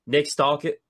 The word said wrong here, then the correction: Stauskas: Say it one more time for me. – Nick Stauskas. – They have Nick Stalkett. What? That's so difficult. Yeah. So Nick Stauskas.